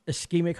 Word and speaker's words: ischemic: Is ischemic heart disease. Am I pronouncing that right? Ischemic heart disease? – Yes.